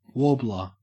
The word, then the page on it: warbler: Australian English